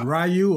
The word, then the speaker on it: Ryu: Is it Ryu or Ru? What is it? Ryu. Ryu.